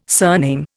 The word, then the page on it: surname: Indian